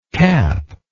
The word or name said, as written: capless